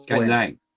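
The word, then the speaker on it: Scanlan: Everyone gets her name wrong, spelled wrong. It's S-C-A-N-L-A-N. Not all. Scanlon.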